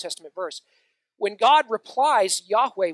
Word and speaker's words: Yahweh: The reason that's in all caps is that is the holy name as revealed by God the pronunciation we typically hear is it's pronounced Yahweh.